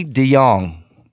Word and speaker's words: de Jong: Frankie de Jong. It's a silent J, right? – Yes. – Like Nigel de Jong, right? – Yeah, so Frankie very, very de Jong.